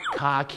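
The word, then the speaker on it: khaki: แต่ภาษาอังกฤษก็จะออกเสียงว่าคาขีนั่นเองครับใช่คล้ายๆกับคาขีนั่นแหละ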